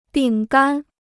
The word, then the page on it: 饼干: bǐng gān